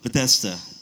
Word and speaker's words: Bethesda: I used to always get the pronunciation wrong. The Pool of Bethesda. Bethesda.